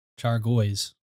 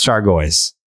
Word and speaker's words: Chargois: Chargois. How do you pronounce that? – Chargoy's. – Yeah, that's Chargoy's.